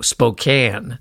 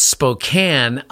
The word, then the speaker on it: Spokane: In particular, the one I remember the most is how I pronounced Spokane. No, Spokane. It's Spokane. I pronounced it Spokane.